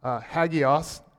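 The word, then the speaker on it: hagios (ἅγιος): It comes from the Greek word that means hagios. That's how you pronounce it.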